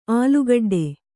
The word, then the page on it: ಆಲುಗಡ್ಡೆ: ālugaḍḍe